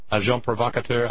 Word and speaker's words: agent provocateur: Agent provocateur, I don't think you pronounce the S's.